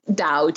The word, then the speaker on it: Dao: Today in 2019, I think the way to pronounce it in a way that shows you're educated and informed would be Dao, D-A-O, and that's because that's the pinyin or the way that the mainland Chinese would pronounce it.